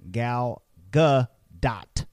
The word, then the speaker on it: Gadot: If you're not pronouncing the letter T in Gal Gadot's name, you're making a mistake. Instead of saying her name as Gal Gadot or Gal Gadot, you should be saying Gal Gadot.